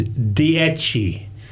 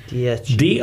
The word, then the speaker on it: Dieci: How did, what's the pronunciation of your restaurant? – The pronunciation is Dieci. – Dieci. – Dieci. And it is the word in Italian meaning 10, the number 10.